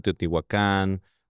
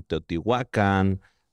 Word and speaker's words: Teotihuacán: Porque está castellanizado. – Ok. – En el náhuatl no existen las palabras agudas. Uh-huh. Por eso es como Tenochtitlán, Teotihuacán. Pero en el náhuatl, en verdad, son casi todas graves.